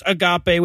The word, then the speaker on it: agape: And we also got a message from all but six of you correcting my pronunciation of agape on last week's quiz show segment. So the Greek word I was referring to is pronounced agape, which means the sloppy agape thing is a little bit closer to a rhyme. And I should have known that because I said it was a Greek word, and I know there's no silent E in Greek.